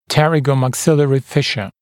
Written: pterygomaxillary fissure

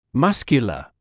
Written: muscular

